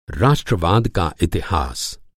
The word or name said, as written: राष्ट्रवाद